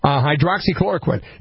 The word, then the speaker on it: Hydroxychloroquine: Hydroxychloroquine. – Yeah. Hydroxychloroquine.